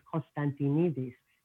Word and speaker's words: Konstantinidis: It's only four syllables because my real last name is Konstantinidis. That's five syllables.